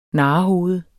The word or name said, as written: narrehoved